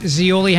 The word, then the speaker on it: Zeoli: It's me, Rich Zeoli, hanging with you today. I have my podcast. You can check it out wherever you get your podcast. Z-E-O-L-I. That's how you spell it. Just like the Olive Garden.